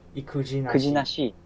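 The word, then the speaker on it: ikujinashi: Am I pronouncing that correctly? – Ukujinashi.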